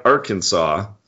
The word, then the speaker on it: Arkansas: Well, I was just doing a little research here, and it says in 1881, the pronunciation of Arkansas, with the final S being silent, was made official by an act of state legislature after an almost violent dispute broke out between two Arkansas U.S.